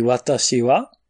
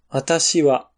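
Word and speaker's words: Watashi wa (私は): Und äh, über Ta, Si und Wa ist ein Strich. Das deutet an, dass das Wa nach dem Wa es eine Erhöhung gibt, also das Ta ist höher als das Wa. Wata, wata. Da geht die Stimme hoch. Und Tashi wa ist dieselbe Tonhöhe.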